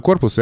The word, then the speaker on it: корпуса: «Корпус» в значении «здания» имеет множественное число корпуса.